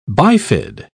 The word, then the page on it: bifid: British English